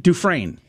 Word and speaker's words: Dufresne: Because Dufresne. – Dufresne. Oh, that's how you pronounce it.